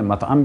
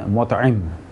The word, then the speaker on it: مطعم: شاید ہم نے سابقہ گفتگو میں جبیر بن مطعم غلطی سے کہہ دیا جو صحیح تلفظ ہے، کیونکہ بعض نے مطعم بھی کہا، لیکن جو زیادہ صحیح تلفظ ہے وہ ہے جبیر بن